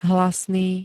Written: hlasný

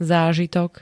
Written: zážitok

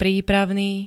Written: prípravný

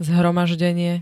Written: zhromaždenie

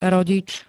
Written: rodič

